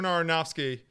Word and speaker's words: Aronofsky: So Darren Aronofsky. – That's how you pronounce his name? – Yeah.